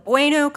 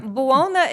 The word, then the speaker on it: Buona: And it was always just like, they would call it Bueno Coffee. – Or Buona. – Well, Buona. Buona is actually how it Pretty should close. Be pronounced in the Italian, but it's just too hard.